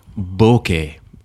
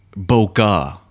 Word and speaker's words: bokeh: now, first of all, we should talk about um, people need to stop saying bokeh, you Americans. Okay, bokeh. It's not bokeh.